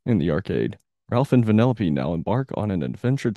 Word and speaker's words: Vanellope: And look at me, I said her name correctly this time. – Good job, I'm proud of you. – I swore up and down that her name was Penelope the whole game. Like, okay, that's a normal girl's name. I didn't think vanilla.